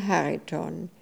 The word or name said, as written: Hariton